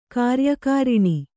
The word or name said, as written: ಕಾರ್ಯಕಾರಿಣಿ